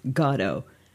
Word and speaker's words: Godot: Well, I first have to say, Norm, I say Waiting for Godot. Okay, I think I'm gonna wager a guess that most of my audience is going to be surprised that the story that is pronounced Godot.